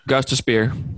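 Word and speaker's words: Gostisbehere: Like, they're having issues with Shane Gostis – how do you say his name? Gostis Bear here? – Gostis Spear.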